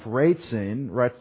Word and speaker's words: Rezin: So the summary of the action, they came up, Ratzin is how you say it in Hebrew, Ratzin,